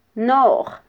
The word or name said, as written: noj